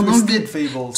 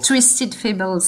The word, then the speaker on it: Twisted Fables: C'est Twist Fables. Je sais pas si je le prononce très bien. ton Twisted Fables. – Twisted Fables.